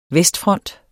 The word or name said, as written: vestfront